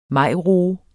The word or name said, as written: majroe